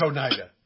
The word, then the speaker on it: Oneida: I've already forgot how to pronounce it, Brother Matt. Oneida? Oneida? – Yeah. – It is Oneida. Okay. Oneida.